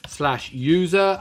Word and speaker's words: usr: Now the next directory I want to go to is /usr, which I said earlier on I pronounce /user, and I will pronounce it /user because that is actually what it is. Okay, way back in the day, this was actually user but without the letter E in it, and then your home directory was actually in /user/users with an E and an S at the end.